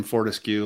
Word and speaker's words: Fortescue: My name is Tim Fortescue.